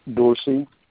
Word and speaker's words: Dorsey: Dorsey Swear Engine. – How do you spell that? – First name is D-O-R-S-E-Y. – D-O-R-S-E-Y.